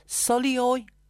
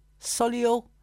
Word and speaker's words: Sulio: A product called, and I'm open to correction if this is how you pronounce it, Solio, S U L I O, Solio.